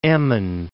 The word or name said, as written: Emmen